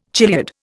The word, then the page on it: chiliad: Indian